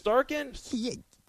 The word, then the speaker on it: Shesterkin: Shesterkin? – That's pretty good. Most people can't even make that pronunciation right on the fifth time.